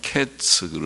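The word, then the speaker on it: 캐츠 (קץ): 히브리어 단어가 캐츠 그럽니다. 카이츠 좀 비슷하죠. 카이츠를 보여주시면서 이스라엘의 캐츠가 왔다 이르렀다 그렇게 교훈하셨어요. 하나님께서는 참 우리가 기억하기 좋게 또 쉽게 일깨워 주시려고 하시는 것 같죠. 캐츠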